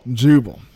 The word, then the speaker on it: Jubal: Jubal.